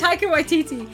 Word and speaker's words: Taika Waititi: Titi Wakaka? What? – Titi. Oh gosh, this is really embarrassing because I can't pronounce the name. – What is it? – Taika Waititi.